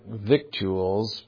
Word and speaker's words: victuals: I know that word looks like victuals, but if you look it up in the dictionary, it's actually pronounced victuals. That's kind of an old form.